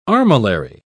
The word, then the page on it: armillary: British English